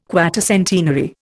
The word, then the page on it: quatercentenary: Indian